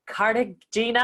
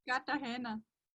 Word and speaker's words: Cartagena: dance salsa with a guy in Cartagena, I can't even pronounce that word. How do I pronounce that? Say it again. – Cartagena.